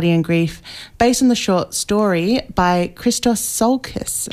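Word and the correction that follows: Tsiolkas: Chalkis. – I need to learn my pronunciation.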